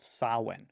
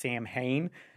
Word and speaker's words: Samhain: Most folklorists will trace it back to a pre-Christian ancient Celtic festival called Samhain. And it's not spelled the way it sounds. It looks like it should be pronounced Samhain, mm-hmm. But it's really Samhain or Samhain, depending on your preference.